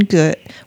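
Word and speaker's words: Goode: Michael Shane Good. Well, I say good. It's G-O-O-D-E. – That's how I'd pronounce it. – But I was watching a program and they pronounced it differently.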